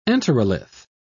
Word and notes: enterolith: British English

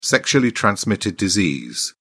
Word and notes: sexually transmitted disease: British English

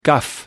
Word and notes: guff: American English

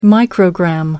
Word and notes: microgram: American English

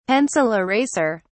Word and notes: pencil eraser: British English

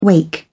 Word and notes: wake: British English

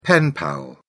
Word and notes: pen pal: British English

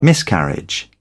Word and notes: miscarriage: British English

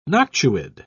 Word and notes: noctuid: British English

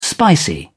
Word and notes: spicy: British English

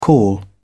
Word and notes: call back: British English

